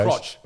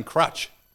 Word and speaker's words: crotch: Crotch. Crotch. You were saying crutch.